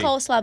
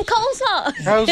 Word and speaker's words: coleslaw: Yeah, it's cold slaw, but maybe someone called it cold slaw. I always thought that came from Southern people just not fully pronouncing the word.